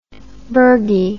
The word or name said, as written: burgie